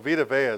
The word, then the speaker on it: Vita Vea: So Vita Vea is the best way to say it.